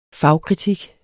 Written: fagkritik

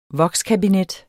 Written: vokskabinet

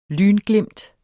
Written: lynglimt

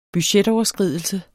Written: budgetoverskridelse

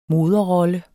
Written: moderrolle

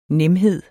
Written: nemhed

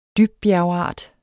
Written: dybbjergart

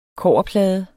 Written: kobberplade

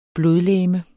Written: blodlegeme

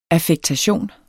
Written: affektation

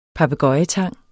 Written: papegøjetang

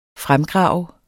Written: fremgrave

